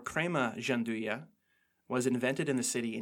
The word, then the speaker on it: gianduja: chopped hazelnuts called gianduja, which I might be saying that wrong, but from what I can best guess, gianduja.